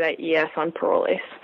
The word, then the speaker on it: Parolles: When we heard it in play, they said Parolles and Lasso. That's how, when I looked it up, it said like the British normally pronounce it.